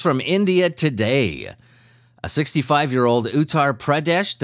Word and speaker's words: Uttar Pradesh: Uttar Pradesh.